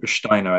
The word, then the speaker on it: Steiner: I mean, there's one kind of pop culture reference that I just want to touch on here, which is the Steiner rant, or Steiner, I think is perhaps the more correct pronunciation,